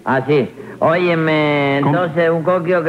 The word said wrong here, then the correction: kiosco: que se llama... Kiosco.